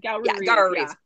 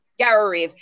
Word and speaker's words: Garoureve: Is that how you pronounce the city? – Garoureve. – Garoureve.